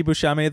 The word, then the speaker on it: Buscemi: and my name is Andy Buscemi. The last name is spelled B-U-S-C-E-M-I.